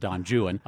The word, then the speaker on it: Don Juan: incompleted work called Don Juan, and he did pronounce it Juan, Don Juan, of course.